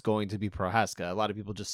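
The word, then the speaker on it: Procházka: It's Prochaska. – Prochaska. – If he's going to be the champ, you got to get the name right, sir.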